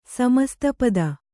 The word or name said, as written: ಸಮಸ್ತಪದ